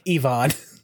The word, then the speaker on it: Yvonne: Which uh, we've been saying her name wrong, apparently. It's Yvonne. Yvonne De Carlo.